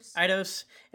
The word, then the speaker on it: Eidos: Eidos Interactive.